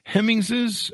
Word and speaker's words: Hemingses: Did I get the Hemingses pronunciation? – You did.